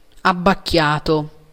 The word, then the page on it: mélancolique: /me.lɑ̃.kɔ.lik/